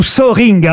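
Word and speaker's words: Söhring: C'est son vrai nom, Hans-Jürgen Zöring. – Il vit en France que... ou Zöring, hein, vous prononcez comme oui. vous voulez. Ça s'écrit S-O-E-H-R-I-N-G.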